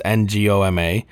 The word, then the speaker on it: Ngoma: Her last name is Ngoma. Yeah, I guess you might call her Gigi because the N is silent, right? So it's probably Gertrude Goma. It's Ngoma.